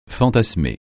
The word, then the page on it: fantasmer: francais